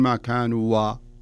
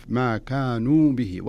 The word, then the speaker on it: كانوا: لاحظ الألف اللي تبعد الواو، ما تقول ما كانوا و، لا تنطقها لا وصلا ولا وقفا، ما كانوا به ولا كأنك انظر إليها.